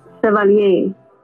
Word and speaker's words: Chevalier: No, it's not pronounced how it looks. It's French. – Let me hear you say it one more time. – Chevalier.